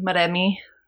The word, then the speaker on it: Moremi: Also, I'm adding Moremi, and they pronounce it Moremi.